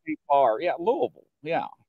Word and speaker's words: Louisville: But he lives in Indiana, and which is right across the bridge from Louisville, or we call it Louisville. But we, you know, it's spelled Lewis.